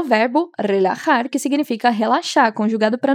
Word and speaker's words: relajar: Lembra sempre que a pronúncia do R é bem vibrante e o J tem esse som de RR do português. Então, repete. Relajar. Relajamos.